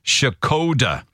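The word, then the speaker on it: Škoda: I think Skoda are pronouncing their own name the wrong way. Well, I told you how Skoda say we should pronounce Skoda cars. It's Shakoda.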